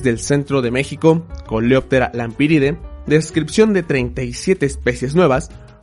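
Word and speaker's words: Lampyridae: Son unos insectos del orden Coleoptera, mismo orden al que pertenecen los escarabajos de la familia Lampiridae, que se escribe Lampiridae, con una Y después de la P y con terminación AE, pero que se, lee Lampiride.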